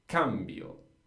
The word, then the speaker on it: cambio: So you, Martin, and everybody at home practice saying just cambio, cambi. Just the a is where the stress goes. Io cambio to cambi, because the a is the second last syllable in this case.